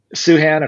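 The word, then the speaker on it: Sochan: Suhan, according to Fran Fraschilla, that's the correct way to pronounce it.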